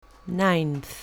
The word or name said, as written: ninth